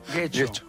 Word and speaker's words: Getxo: Getxo.